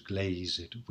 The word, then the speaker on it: glazed: Again, it is not uncommon for Shakespeare to use a personal pronoun for an object, and glazed is pronounced with two syllables, that hath his windows glazed with thine eyes.